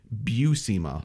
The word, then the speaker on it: Buscema: We find out that you pronounce John Buscema's name Buscema. – Buscema, not Buscema.